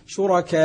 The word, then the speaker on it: شركاء: الحقيقة ما نقلناه عن شيوخنا في مثل هذه المسائل هي قضية أن الإنسان عندما ينطق الإشمام هنا يقول مثلا طريقة الإشمام شركاء، حيث أشعر القارئ أن الواو مضمومة، يعني إذا قلت شركاء هذه ساكنة، لكن يقول شركاء